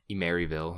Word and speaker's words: Emeryville: By Other Ocean, Emerville. That's how it's pronounced, Emeryville or whatever.